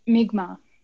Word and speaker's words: Mi'kmaq: the Mi'kmaq lobster conflict. Is that how you would pronounce? – You'd pronounce it Mi'kmaq.